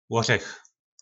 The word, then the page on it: lorech: po nasimu